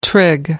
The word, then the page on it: trig: American English